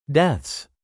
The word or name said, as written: deaths